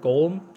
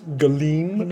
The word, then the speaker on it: Golem: It's actually pronounced Galeem. – Galeem. No, it's Galeem. I gotta get in the throat there.